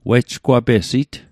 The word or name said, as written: wejgwapesit